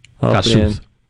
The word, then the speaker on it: Kossuth: Kossuth. – How you say it? – Kossuth. – Oh, I always said Kossuth. I didn't know that.